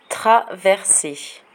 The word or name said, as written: traverser